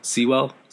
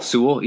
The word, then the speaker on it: Sewell: Panay Sewell? – Sewell. – Sewell, yeah.